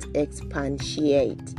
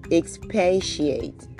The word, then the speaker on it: expatiate: Okay, the word is not expatiate. It's expatiate. Expatiate.